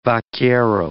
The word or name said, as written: vaquero